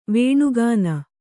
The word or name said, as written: ವಣಖಾಣ